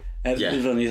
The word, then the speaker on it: Villeneuve: I always pronounce the Villeneuve, but it's not. – Yeah. – It's not. Your man did like Sicario, Enemy. – Villeneuve, thank you.